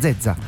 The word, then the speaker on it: Zezza: Ah, Zezza. Senza l'accento.